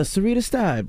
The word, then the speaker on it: Syreita Steib: Senate of Louisiana, Syreita Steib.